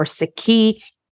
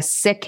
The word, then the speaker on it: Sikh: When I say Sikh, my faith is called Sikhism or Sikhi. I am a Sikh. It's spelled S-I-K-H. We're, as we try to decolonize language, it's properly pronounced as Sikh and not Sikh.